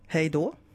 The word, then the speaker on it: Hej då: Hej då.